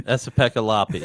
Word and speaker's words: Esa-Pekka Lappi: Esa-Pekka Lappi.